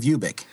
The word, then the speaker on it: Ubik: Ubik, is that the way you pronounce it? – Yeah, Ubik.